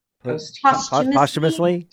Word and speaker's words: posthumously: He wants to write his will posthumously. – Yes. – Is that how you pronounce that word? Humus? – Posthumously? – Posthumously? – The emphasis. The emphasis was wrong.